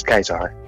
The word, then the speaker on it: Caesar: Dat de Romeinse letter C eigenlijk moet uit worden gesproken als een K. En dat is heel, dat merk je vooral bij Julius Caesar. Dat moet je eigenlijk gewoon uitspreken als Julius Keizer.